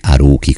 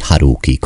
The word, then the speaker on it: Haruki: Io vorrei sottolineare il fatto, altro piccolo particolare, che Antonietta Pastore non ha detto Murakami Haruki come diciamo noi, ma ha detto Murakami Haruki con la H aspirata.